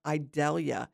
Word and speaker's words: Idalia: So we're going to be talking about what to do to prep in case of Idalia. And so Idalia is spelled like the onion without the V.